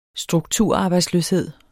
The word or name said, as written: strukturarbejdsløshed